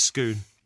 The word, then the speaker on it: Scone: It's spelt scone, like off of jam, but it's scone.